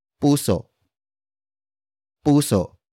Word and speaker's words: puso: It is the focus marker that introduces the main topic of the sentence, which is puso. Puso.